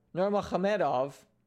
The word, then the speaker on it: Nurmagomedov: So I'm going to be watching Justin, the highlight Gaethje, versus Khabib, the Eagle, Nurmagomedov. And yes, that is the proper pronunciation of both his first and last name.